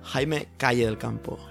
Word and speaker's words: Jaime Calle del Campo: Jaime Calle del Campo.